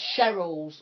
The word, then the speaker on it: Cheryl's: Oh, and just to say, it's Cheryl's World, not Sherry L. Sherry LS, because obviously it's spelled S-H-E-double-R-Y-L. Yeah. Yeah. I get, hi, Sherry, no, I don't know where you think you're going, just removing the L from my name like that.